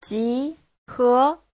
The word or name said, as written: ji2he2